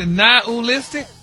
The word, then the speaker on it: nihilistic: Nihilistic? – Wow. – Stop putting the ooh in there. – Not a-listic? – There you go.